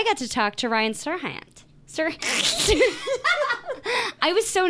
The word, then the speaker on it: Serhant: I made Molly look up how to say his name because I never know if it's Haunt or Hant. – What is it? – Serhant. – Serhant.